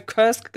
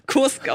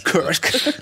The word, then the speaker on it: Kursk: Im Gegenteil, ich habe noch sehr lange Kursk gesagt, bevor ich dann, bevor ich dann wusste, dass es Kursk ausgesprochen wird. Kursk.